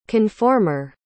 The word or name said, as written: conformer